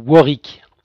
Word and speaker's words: Warwick: il y a donc les travaux de Kevin Warwick, je, je prononce correctement alors, c'est un faux ami, ça se prononce Warwick.